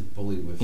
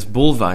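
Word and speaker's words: Buliwyf: Okay, it says Bully Wolf. – Yeah, but it's Bulvi is how they pronounce it in the film.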